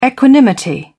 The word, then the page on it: equanimity: British English